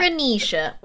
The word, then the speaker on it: Macaronesia: I can't pronounce that. Macaronesia, which I only know from watching so much Survivor.